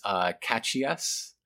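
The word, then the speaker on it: Caxias: That prison should have been pronounced Cachias. That's how the X goes.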